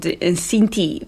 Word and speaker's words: Xinti: It's titled Sinti. It's spelled X-I-N-T-I.